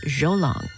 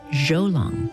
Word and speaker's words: Zhou Long: We're always glad to share music by Chinese-American composer Zhou Long. And by the way, his first name is not J-O-E, it's spelled Z-H-O-U, Zhou Long.